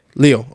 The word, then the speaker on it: Liu: Leo.